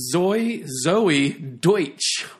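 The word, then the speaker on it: Zoey Deutch: Next on our list of horror news, Zoe Deutsch, right? Am I saying that right? – Correct.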